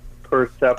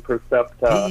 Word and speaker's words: Percepta: And Percepta is spelled, let's just get the spelling right so everybody knows, P-R-E-C-E-P-T-A? – No, P-E-R-P-E-R-C-E-P-T-A. Percepta, like perception, Percepta. – P-E-R-C-E-P-T-A.